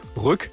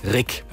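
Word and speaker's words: Ryck: Und hier in Greifswald haben wir einen Fluss, der wird auch gerne mal Rück oder Riek ausgesprochen, der heißt aber Rick.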